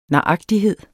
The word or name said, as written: naragtighed